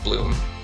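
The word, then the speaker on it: 8LOOM: yeah, listeners, only listeners won't get that, but yeah, it is spelled eight, L O O M, but pronounced like bloom.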